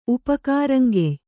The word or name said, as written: ಉಪಕಾರಂಗೆಯ್